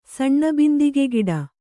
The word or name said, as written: ಸಣ್ಣಬಿಂದಿಗೆಗಿಡ